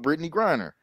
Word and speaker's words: Brittney Griner: We talking about Brittany Griner. I said it right this time. Y'all heard me. I didn't say, I left the D out. I omitted the D.